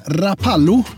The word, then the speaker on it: Rapallo: Hon är född i Stockholm, men uppväxt i, nu ska vi se så jag uttalar detta rätt här, Rapallo. Det stämmer bra det.